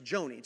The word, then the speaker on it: Joni: But she came and she said, you know it's pronounced Johnny Erickson Tada. I said, no, it's not Johnny, it's Joni.